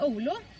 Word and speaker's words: Oulu: Vi hade ju en tjej från Finland, Annie, från Finland då i Oulu. Tror jag det är man uttalar det. – Ja, precis.